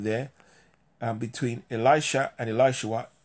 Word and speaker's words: Elisha: So we see that Elisha, there is a contracted or a shortened spelling for what we would pronounce Elishua.